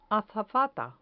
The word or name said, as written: azafata